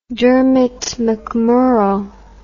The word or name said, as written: Dermot MacMurrough